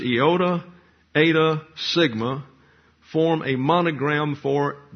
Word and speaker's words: eta: The H is the second letter in the Greek word for Jesus called an eta, and it's pronounced like a long English A.